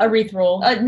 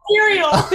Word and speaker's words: ethereal: Erythral. – No, not Oh. Not that. – Ethereal? – Ethereal.